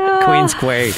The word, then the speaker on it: Queens Quay: Queens Quay. Yeah, that's exactly how you pronounce it.